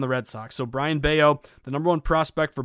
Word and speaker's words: Bello: And his last name is spelled B-E-L-L-O. It's not Bello, it's Bello. Again, make sure you roll your L's, roll your R's. But Bello, it's like silent. It's not Scott Bello, it's Brian Bello.